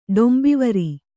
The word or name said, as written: ಡೊಂಬಿವರಿ